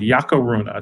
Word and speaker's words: Yacuruna: It's called Yacaruna. It's spelled Y-A-C-U-R-U-N-A, Yacaruna.